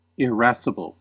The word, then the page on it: irascible: American English